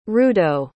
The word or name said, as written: rudo